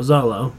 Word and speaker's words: Xolo: X-O-L-O. Zolo. – Oh, yeah. X-O-L-O. – Like when I read comics as a kid, I pronounced the X and everything. Yeah. – X-O-L-O. X-O-L-O Mandalorian.